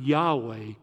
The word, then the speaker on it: Yahweh: The personal name for God that God revealed to Moses at the burning bush in Hebrew is spelled YHWH. We pronounce it Yahweh.